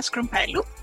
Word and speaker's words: Skroumpelou: And my name is Katerina Skroumpelou.